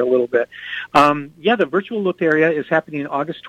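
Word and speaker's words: Lotería: Yeah, Loteria.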